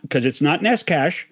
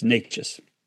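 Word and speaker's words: Nečas: And so I say, it's not Neskash, it's close to nachos, and then I say, yes, it's Natchez.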